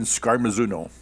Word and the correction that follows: Scarmazino: How do you say it, Phil? You covered them back in Scarmazino. – Scarmazino, yeah. – Scarmazino, yes.